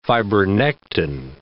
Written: fibronectin

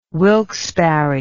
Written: wilkes barre

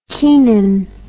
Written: keenan

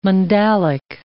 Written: mandalic

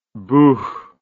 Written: buch

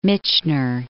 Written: Michener

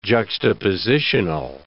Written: juxtapositional

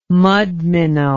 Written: mudminnow

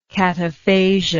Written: cataphasia

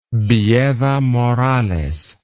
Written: villeda morales

